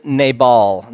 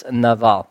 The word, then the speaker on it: Nabal: His name is Nabal. That's usually how it's pronounced. It's in Hebrew it's Nabal. and it is uh, Nabal. Nabal.